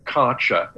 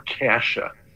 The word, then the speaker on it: Kärcher: The problem is that there's an umlaut sitting over the top of it's a German brand, and it looks like being Karcher is how you would look at it phonetically, but I think it's more like Kärcher. – Kärcher. I would go with Kärcher. Let's go with Kärcher. K-A-E-C-H-E-R.